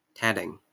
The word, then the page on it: tatting: /ˈtætɪŋ/